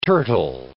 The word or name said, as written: turtle